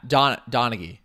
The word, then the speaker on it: Donaghy: Yeah, Donaghy.